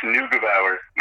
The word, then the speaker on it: Neugebauer: It's Neugebauer.